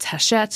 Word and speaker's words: Hachette: If you go to the Hachette website, and that's Hachette Books, it will be linked in the show notes because it's spelled like it looks like hatchet, but it's Hachette because it's fancy.